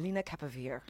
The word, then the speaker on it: lenacapavir: How do you say it? Lenacapavir. – I think it's Veer, actually. – Veer, I've been practicing the whole afternoon. Lenacapavir.